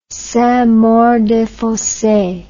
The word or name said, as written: saintmaurdesfosses